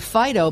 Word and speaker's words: Phydeaux: Well, I had a dog named Fido, but it was spelled P-H-Y-D-E-A-U-X. – I'm putting a little extra on that. – Fido. – Yeah, well, to pronounce it with the French pronunciation. – Yep. Fido. Fido. P-H-Y-D-E-A-U-X.